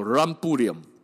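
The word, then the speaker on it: Rumbullion: 最早的记录不是 R U M，这种饮料叫做 R U M B U L L I N，应该发音叫做 Rambouillet。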